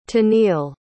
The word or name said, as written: teneil